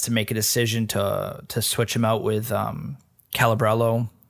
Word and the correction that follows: Caballero: Am I saying that wrong? – Willy Caballero. – Caballero.